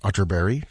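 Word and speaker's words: Atterberg: It's spelled Otterberg, but pronounced Otterberg, just in case you were Googling.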